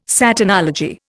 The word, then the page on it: Satanology: Indian